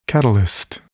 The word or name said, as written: catalyst